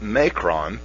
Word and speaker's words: Macron: Their president, Macron, that's how you say it, here's a quote from him, and it was in this similar article, Jason, about Merkel.